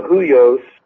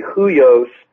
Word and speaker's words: huios: And there in the notes, I cite Galatians 4, which discusses the difference between a huios, which that H-U-I-O-S actually is pronounced in Greek with a W, and nepio, which is a little child.